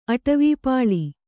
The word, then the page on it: ಅಟವೀಪಾಳಿ: kannada